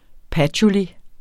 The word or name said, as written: patchouli